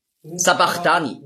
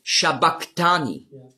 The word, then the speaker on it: Sabachthani: Sabachthani lesen viele. Aber das heißt eigentlich auf Aramäisch ausgesprochen ist es Shabachthani. Und das ist das CH, das Chi, ist im Griechischen als K ausgesprochen worden. Aber das S steht eben für den Sch-Laut. Und darum war die Aussprache ist Eli, Eli, Lama Shabachthani.